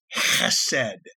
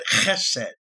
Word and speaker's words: hesed: The Hebrew word is chesed. It's how the Jews pronounce it, chesed. It's spelled H-E-S-E-D.